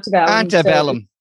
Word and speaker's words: Antebellum: What was the Appabellum or something? I'm pronounced. – Antebellum. – Antebellum.